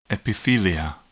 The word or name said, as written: epithelia